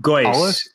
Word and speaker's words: Geuss: So I thought that, well, I don't know how to pronounce that name, Geuss and Geuss is the way Geuss is how he pronounces it. Geuss, it looks like Geuss, and anyone who hasn't heard the name can't be faulted for pronouncing it Geuss.